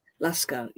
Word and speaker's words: Lashkar: And it seems like you consider it very important to tell those stories, because one of your books, how do you pronounce it? Lashkar? – Lashkar, yes. – In Urdu we say Lashkar.